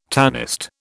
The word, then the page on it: tanist: British English